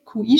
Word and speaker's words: Qi: Aber ich schwäche schon von in der Früh weg meinen Qi-Fluss, also Qi. QI schreibt man das, das ist ja im Chinesischen die Energie, bei uns geht es ja immer um das Qi, das richtig fließen soll.